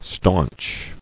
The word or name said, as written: Staunchness